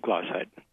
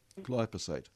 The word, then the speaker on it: glyphosate: And plants are developing resistance to glyphosate. Why do you pronounce it? – Glyphosate. – Glyphosate. Yep.